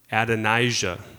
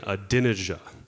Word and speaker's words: Adonijah: And I don't know if you remember, but I was pronouncing the name wrong. I was saying Adonijah, and I have no idea why I thought that was how you pronounced it. So for those of you who were laughing and didn't tell me, I appreciate that. But I did get corrected. It is Adonijah.